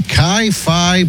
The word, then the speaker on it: Chi Phi: She claims that she was repeatedly sexually assaulted during a party at the, is it Chi Phi? Is that how you pronounce it? Chi Phi? Chi Phi Fraternity House. C-H-I, that's Chi.